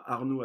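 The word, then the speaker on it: Arnault: Euh, avec euh, mon prénom et mon nom, hein, Arnaud avec LT, car je suis breton et en Bretagne, ça s'écrit avec LT à la fin, donc Arnaud Châtel.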